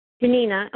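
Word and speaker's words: Ginina: Janina. Mm-hmm. – Can you spell that? I'm sorry. – D-I-N-I-N-A. – One more time. Is it D or G? – G as in giraffe. – Okay. – I-N-I-N-A. – I-N-I-N-A.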